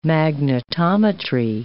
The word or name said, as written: magnetometry